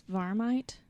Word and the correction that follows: Marmite: Marmite. It's yeast extract.